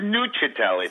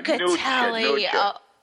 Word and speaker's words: Nuccitelli: It's Nuccitelli. I think uh, Italian. Nuccitelli.